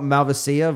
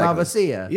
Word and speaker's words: Malvasia: I mean, we're talking about Malvasia versus Malvasia. – Yeah. – Yeah. Well, the Italians often accentuate it more. It's exactly. Malvasia. – Yeah. – It's an extra syllable.